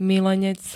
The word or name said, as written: milenec